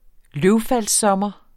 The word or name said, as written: løvfaldssommer